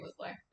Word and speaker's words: Mugler: Mugler? Is that how you say it? – Yeah, Terry Mugler. – Oh, I thought it was, like, French. – It is, but, I mean, Terry Mugler.